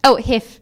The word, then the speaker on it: HIIF: H-I-I-F or HIF? – HIF.